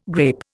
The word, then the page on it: grape: Indian